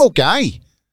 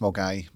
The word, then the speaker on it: Milngavie: M-I-L-N-G-A-V-I-E. – Oh, right, okay, that's interesting. Yeah. So you're not pronouncing it the way it's spelled.